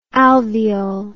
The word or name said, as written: alveole